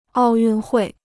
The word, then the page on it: 奥运会: ào yùn huì